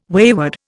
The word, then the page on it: wayward: Indian